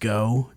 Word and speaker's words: Goux: I believe it's pronounced Go, mm-hmm. G-O-U-X.